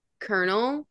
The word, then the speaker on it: colonel: Probably kernel, because it's spelled C-O-L-O-N-E-L, but then the pronunciation just does not make sense at all.